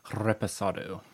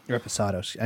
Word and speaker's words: Reposado: They don't have their gin on our website, or the other one, the Respondo. – Reposado. – Reposado. I knew I said it wrong.